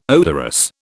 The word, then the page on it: odorous: British English